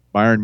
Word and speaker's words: Bayern: It was FC Bayern. Is that how you say it? – Bayern Munich, yeah.